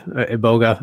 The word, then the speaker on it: iboga: I've heard it pronounced as iboga. – Yeah, iboga. Okay. I think ibogaine is the actual molecule. Iboga is the tree, I think.